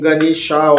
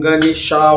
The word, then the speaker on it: Ganesha: Om Ganesha, Om Ganesha, Om Ganesha, Om Ganesha, Om, Om, Om, que é a pronúncia correta de, do mantra.